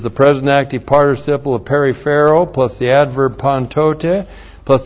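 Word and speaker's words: Periphero (περιφέρω): We start out with the verb periphero. Looks like this in the Greek. Periphero, P-E-R-I-P-H-E-R, long O.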